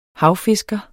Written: havfisker

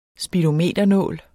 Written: speedometernål

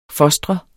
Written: fostre

